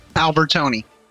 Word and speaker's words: Albertoni: Albertoni.